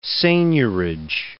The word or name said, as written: seigniorage